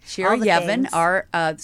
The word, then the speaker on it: Shira Yevin: Shira Yevin. So let's spell it Shira for those just listening and not watching us. And then it's Yevin.